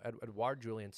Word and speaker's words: Edouard Julien: And then we have Edouard Julien. Did I say that right? Is it Edouard? – Yeah, you said it right. – Edouard Julien.